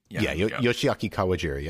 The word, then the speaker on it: Yoshiaki Kawajiri: Yoshiaki Kawajiri.